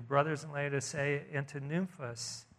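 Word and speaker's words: Nymphas: And then he says this, and the way to pronounce this is Nymphos. I'll just kind of transliterate. It's N-Y-M-P-H-A-S. Nymphos is her name.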